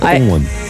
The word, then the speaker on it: Akon: Akon, ouais, pardon, je prononce ça à la française. – Akon, c'est pas pareil. – Akon.